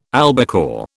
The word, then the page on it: albacore: British English